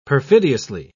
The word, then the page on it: perfidiously: British English